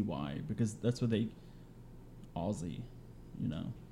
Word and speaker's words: Aussie: Spell it because it sounds like you're saying Aussie. – Yeah, like Aussie, like uh, Australian.